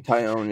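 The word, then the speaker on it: Taillon: They traded for Pittsburgh's Jameson, is it pronounced? Tyone? – Okay. Jameson Tyone, yep.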